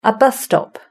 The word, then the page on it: a bus stop: British English